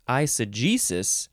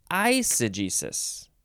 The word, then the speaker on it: eisegesis: You can also say, what is the difference in, we got, oh, you can, okay, so you're either emphasizing the G, eisegesis, or you're emphasizing the first syllable, eisegesis. I see Jesus. I see Jesus. E-I-S-E-G-E-S-I-S.